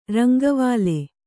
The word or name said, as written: ರಂಗವಾಲೆ